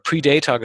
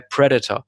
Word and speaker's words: Predator: Oder wie Kinder, wie ich es damals waren, nicht Predator, sondern Predator gesagt haben. Man hat es damals mal falsch ausgesprochen in Deutschland. Predator, mal gesagt. Aber er heißt tatsächlich Predator, das Haupttier. Ja, also immer Predator, ne, weil das immer die Silber, die erste Silbe falsch betont.